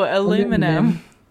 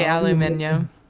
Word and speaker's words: aluminium: Aluminium. How do you say it? – No, aluminum. You say aluminium.